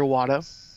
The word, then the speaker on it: Iwata: Mr. Iwata. I'm probably mispronouncing his name. – No, you got it. Satoru Iwata.